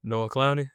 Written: Noah Clowney

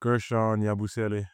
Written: guerschon YABUSELE

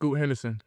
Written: Scoot Henderson

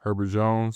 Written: Herbert Jones

